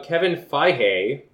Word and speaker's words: Kevin Feige: Anyway, so Kevin Feige, which I think I pronounced that correctly.